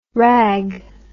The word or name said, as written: ragg